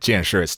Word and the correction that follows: 见识: it's 见识. – 见识. The 识 doesn't have any tone.